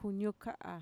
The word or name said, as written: kuñuka'a